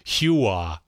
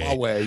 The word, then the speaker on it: Huawei: It's like me saying Hua instead of Huawei. – Huawei, yeah. Right. – And you guys going, no, that's not how you pronounce it.